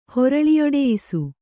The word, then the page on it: ಹೊರಳಿಯೊಡೆಯಿಸು: Kannada